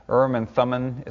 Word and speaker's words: Urim and Thummim: Dividing the land by lot may have involved roulette, and I don't know how Pastor Steve pronounced this, Urim and Tumim. This is the Hebrew way of saying it, but I'm not going to get caught up in that. Urim and Thummim is fine by me.